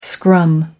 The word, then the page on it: scrum: American English